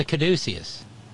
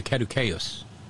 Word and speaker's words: caduceus: It's a caduceus. Or if you want the Latin pronunciation, it's a caduceus.